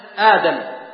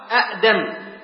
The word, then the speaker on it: آدم: مثلا عندنا آدم أصلها أأدم، فهي لا تنطق أأدم إنما تحذف الهمزة الأولى وتبدلها مد، وهذا سمي مد البدل، قال آدم.